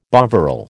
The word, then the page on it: Bovril: American English